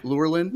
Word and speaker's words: Lurelin: Near Lurelin Village, how you pronounce that? – Yeah. – Lurelin? – Yes, yeah.